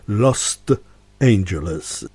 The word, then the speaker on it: Lost Angeles: Zespół Colosseum i utwór zatytułowany Lost Angeles. Specjalnie akcentuję to T na końcu, żeby nie przywoływać bezpośredniego skojarzenia z Los Angeles.